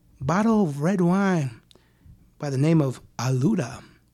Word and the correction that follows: Aluado: Aluado. – Aluado. – Aluado Alicante Boshi. – Which is spelled, for any of our Braille listeners, A-L-U-A-D-O. – Where does this wine come from? – This wine is from Portugal.